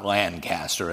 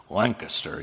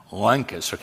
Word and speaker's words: Lancaster: It's not Lancaster, it's Lancaster. You kind of, Lancaster, kind of like a wrench that drops, Lancaster, Pennsylvania.